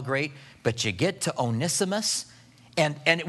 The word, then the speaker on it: Onesimus: Verse 10, "I appeal to you for my son Onesimus, I guess we'll call him today. How you pronounce it, people pronounce it differently, but we'll call him Onesimus. "For my son Onesimus."